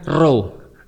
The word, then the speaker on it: rho: Dan P dalam bahasa Yunani bacanya rho. – Rho.